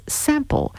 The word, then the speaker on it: simple: The artist is Alain Mion, and the tune is called Simple Sample, which is funny because in French, you say you pronounce simple as sample.